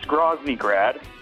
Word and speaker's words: Groznygrad: Not Groznygrad, like you pronounce it. It's Groznygrad.